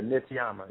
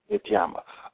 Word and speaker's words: Nityama: Yeah, Nityama. Yes. Nityama.